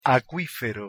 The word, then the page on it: acuifero: Spanish